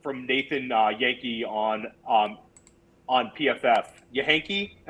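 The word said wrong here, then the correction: Jahnke: am I saying that correctly? Um, Dan Yankee, right? – Janky. – Janky. Okay. All right.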